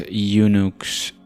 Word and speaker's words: Unix: The group coined the name Unix, UNICS, for Uniplexed Information and Computing Service, pronounced Unux, EUNUX, as a pun on Multics, which stood for Multiplexed Information and Computer Services.